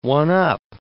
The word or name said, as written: one-up